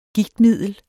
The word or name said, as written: gigtmiddel